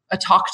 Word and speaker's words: Tawk: One thing we added more recently was a talk to live chat. It's spelled TAWK, somewhat like talk, which the Americans should appreciate especially.